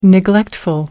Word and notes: neglectful: American English

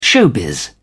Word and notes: showbiz: British English

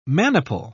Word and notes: maniple: British English

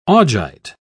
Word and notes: augite: British English

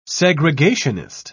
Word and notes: segregationist: British English

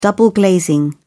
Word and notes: double glazing: British English

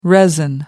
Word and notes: resin: American English

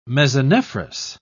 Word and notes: mesonephros: British English

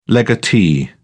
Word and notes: legatee: British English

